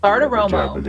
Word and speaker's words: Bartiromo: At number 10 on the people list, Maria Bartiromo. Do I pronounce that right? I never did. – Bartiromo. Big Bartiromo.